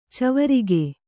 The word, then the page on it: ಚವರಿಗೆ: cavarige